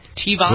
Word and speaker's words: Teavana: It's called, is it Teavana Sparkling Craft Iced Teas? – Teavana. – Blackberry Lime. What's that? Is that my Teavana? Am I pronouncing it correctly? – Yeah. – That is correct.